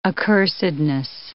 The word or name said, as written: accursedness